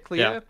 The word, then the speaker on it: Clea: Is that how you say it, Clea? – Yeah.